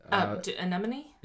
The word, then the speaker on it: anemone: Inhabited by octopus and many species of fish and covered in sea anemones... – Anemone? – Yes, anemone. Thank you.